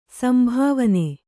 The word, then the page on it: ಸಂಭಾವನೆ: sambhāvane